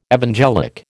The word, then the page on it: evangelic: American English